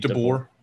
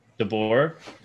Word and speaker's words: DeBoer: Pete DeBoer, how do you say his last name? – Pete DeBoer. – DeBoer.